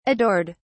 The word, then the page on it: adored: British English